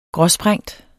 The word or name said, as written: gråsprængt